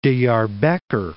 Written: Diarbekr

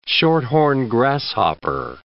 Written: short-horned grasshopper

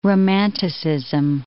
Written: romanticism

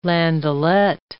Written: landaulet

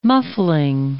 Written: muffling

